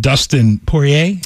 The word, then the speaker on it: Poirier: There is a UFC rematch for Conor McGregor and Dustin Poirier. – Yeah. – Am I saying that right? – Yes.